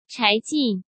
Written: Chai Jin

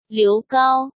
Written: Liu Gao

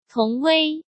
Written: Tong Wei

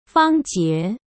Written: Fang Jie

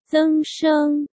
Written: Zeng Sheng

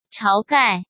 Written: Chao Gai

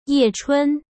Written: Ye Chun

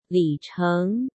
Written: Li Cheng

